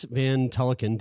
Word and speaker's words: Van Tulleken: Yeah, we are sitting down with Dr. Chris Van Tulleken.